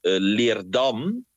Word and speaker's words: Leerdam: En vervolgens waren ook de steden, de dorpen of steden Leerdam. Zeg ik het goed? Ja. Weer glasfabriek Leerdam.